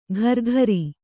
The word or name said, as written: ಕರಕರಿ